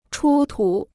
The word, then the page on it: 出土: chū tǔ